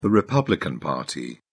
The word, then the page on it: republican party: British English